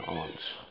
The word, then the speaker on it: aunt: But I'm pronouncing it deliberately, almost in a British pronunciation, aunt. So you understand the difference.